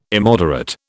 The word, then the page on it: immoderate: British English